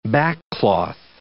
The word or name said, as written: backcloth